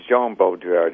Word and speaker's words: Jean Baudrillard: Just to get the pronunciation, it's Jean Baudrillard. He's French.